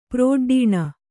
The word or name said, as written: ಪ್ರೋಡ್ಡೀಣ